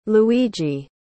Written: Luigi

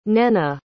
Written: nnenna

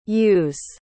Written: yous

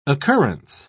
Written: occurrence

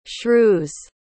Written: shrew's

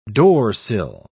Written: doorsill

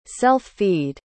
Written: self-feed

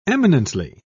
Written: eminently

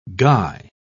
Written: guy

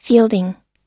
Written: fielding

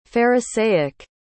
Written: pharisaic

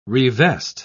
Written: revest

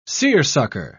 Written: seersucker

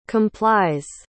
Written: complies

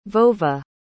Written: Vova

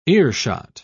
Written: earshot

